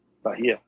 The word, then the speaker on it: Bahia: Is it Bahia? – Bahia, yeah. – The Brazilian, Bahia. Yep. – Bahia, Brazil. It is a 6x50 Toro featuring a Brazilian Maduro wrapper, Nicaraguan binder, and Nicaraguan filler.